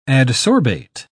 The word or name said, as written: adsorbate